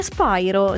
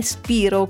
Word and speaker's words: Spyro: E poi le altre curiosità riguardano la pronuncia del suo cognome, che è Spyro, non è Spiro come in tanti pensano.